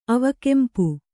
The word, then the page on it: ಅವಕೆಂಪು: avakempu